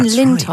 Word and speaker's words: Lintott: They are getting the Sky at Night presenter and astrophysicist Dr. Chris Lintott, is that right? Is that how you say his name? Lintott, right.